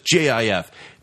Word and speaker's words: GIF: it's a GIF and it's spelled GIF, but it's JIF.